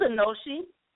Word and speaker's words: Anoshi: It's Anoshi.